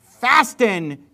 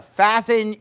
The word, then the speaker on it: fasten: like hold him fast or fasten your seatbelts. I pronounced the T there just to make a point. You don't normally pronounce that T, kids. So, you know, fasten your seatbelts and hold him fast, bind him fast.